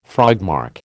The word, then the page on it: frogmarch: American English